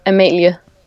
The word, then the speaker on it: Amalie: Well, that's what I usually say because it's easier. But if you know, if you ask my mother, it's Amalie.